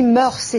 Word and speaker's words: mœurs: La règle officielle est de ne pas prononcer le S final. Même si mœurs est un nom féminin, pluriel, invariable, on doit dire mœurs.